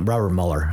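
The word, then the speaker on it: Robert Muller: I ended up writing a letter to Robert Mueller, who was the Assistant Secretary General of the United Nations. Uh, Robert Mueller, actually, okay. is kind of the way it's pronounced.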